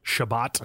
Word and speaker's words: Chabot: How do you say his name? C-H-A-B-O-T? – Shabbat. Shabbat. Thomas Shabbat.